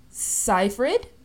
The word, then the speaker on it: Seyfried: Amanda Seyfried, I believe. Yeah, that's how you pronounce it.